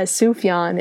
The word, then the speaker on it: Sufjan: Yeah, Sufjan. It's like Suf and then Yon.